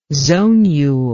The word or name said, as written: zonule